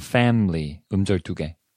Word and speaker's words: family: family 음절 두 개. – 그치. 음절 두 개. 네. 영어에서는. 네. 한국에서는 비록 그 F를 갖다 제대로 발음한다 치더라도 family 이렇게 세 개로 발음을 하는 사람들이 많거든.